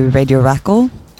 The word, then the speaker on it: radiOrakel: The name is composed of two parts. So it's Radio and Rackle, which is a women's name, obviously. I think most people, myself included, pronounce it in two different ways, depending on who we're talking to, I think. In Norwegian, I'd say Radio Rackle, Radio Rackle, just like in one word.